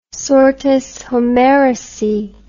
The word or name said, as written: sortes homericae